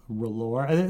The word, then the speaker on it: R'hllor: There's an apostrophe in there, so I don't really know how. Like, I've never heard anyone try to pronounce the apostrophe. Most people that I talk to just say R'hllor, but would it be like R'hllor or something? Because there's like a glottal stop?